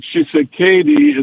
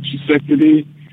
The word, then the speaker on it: Tshisekedi: I was raised in Kasai, where he's from, and Jimmy Kimmel's pronunciation, Tshisekedi, is actually common among foreigners and people in the capital city, but the accurate pronunciation is Tshisekedi. And Trump actually came close to that.